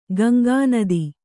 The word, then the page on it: ಗಂಗಾನದಿ: Gaŋgā nadi